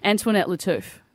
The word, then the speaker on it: Antoinette Latouf: Antoinette Latouf. She does that show. She's actually an award-winning journalist. So is she? – Yeah. – Did you get her name right? – I did, yeah.